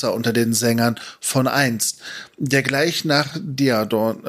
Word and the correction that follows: Daeron: Daeron und Doriath genannt wird.